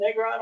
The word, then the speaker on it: Negron: My guest is Chris Negron.